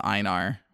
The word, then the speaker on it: Einar: And then the other guy, his name is Einar, which doesn't really have an English equivalent, and that's kind of how you say it either way, so.